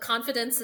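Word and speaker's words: Confidents: So it's called Project Confidence, but Confidence is spelled C-O-N-F-I-D-E-N-T-S, D-E-N-T-S. So Confidence, but Confidence.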